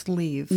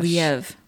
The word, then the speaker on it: Liev: It's Liev. – No. – You looked it up? – I did. In fact, I watched him pronounce his own name. – Oh my gosh. – Liev.